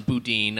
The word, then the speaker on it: boudin: We should get to our final thoughts on boudin, our experience here today. – Is that, before we continue, yes. Boudin is the way to pronounce it. I've heard some people say boudin.